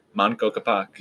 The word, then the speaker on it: Manco Capac: Manco Capac.